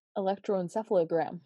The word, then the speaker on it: electroencephalogram: Electro- electroencephalogram.